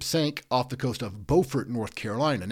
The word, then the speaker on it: Beaufort: Now, there is a city with the exact same spelling in South Carolina, but there it's pronounced Beaufort. I'm here to let y'all know this important stuff. Beaufort, North Carolina, Beaufort, South Carolina, spelled the same.